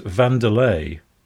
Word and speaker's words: Wanderley: Walter Wanderley, or as I discovered recently, his surname is actually pronounced Vanderlei.